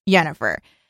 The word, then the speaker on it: Yennefer: Yennefer, and. – It's a soft J. – It's spelled with a Y.